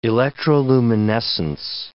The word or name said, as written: electroluminescence